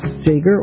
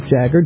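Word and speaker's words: Jaeger: My guest today is Sam Jager, or Jagger, depending on whether how you're going to pronounce it, whether you want to do the German pronunciation or his pronunciation or both.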